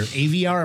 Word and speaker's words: Aviar: Now, one of the tunes we played from earlier in your recording discography was something related to your disc golf career, Aviar.